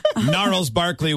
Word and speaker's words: Gnarls Barkley: And I'm thinking the audio clue is the song Crazy by Genghis Barkley or whatever. I can't really pronounce it. – Genghis Barkley. – Genghis Khan was like, you're Asia and stuff. Gnarls Barkley was guy who wears little hat.